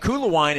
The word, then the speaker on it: Koelewyn: And Kulawine is not pronounced how it is spelled. It is K-O-E-L-E-W-Y-N.